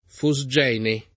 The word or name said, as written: fosgene